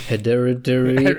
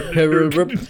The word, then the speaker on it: Hereditary: Aber ist nicht Hedare. Ach Gott, ich kann das also nicht aussprechen. – Hereditary. – Hereditary.